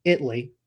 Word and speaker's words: Italy: Actually, it's Italy. They do not pronounce the A. Learned that the hard way.